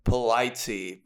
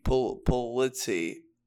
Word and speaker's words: Polizzi: They're like Polizzi, Polizzi. That's actually how you say it.